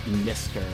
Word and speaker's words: MZZTR: And to start off the set, Mr., spelled M-Z-Z-T-R, out of New York City, the song Kimberly from their brand new EP, pronounced Mr. And like I said,